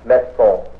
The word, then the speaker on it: maison: Maison. – Non, c'est le Z. Z. Maison. – Maison. – Maison. You see, when the S is between two vowels, you pronounce it like a Z.